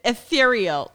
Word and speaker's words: ethereal: The feeling of itching with my ear, no, the feeling of itching my ear with my vibrator is honestly erythreal. I can't pronounce shit. Ethereal. What is it? – Yeah, ethereal.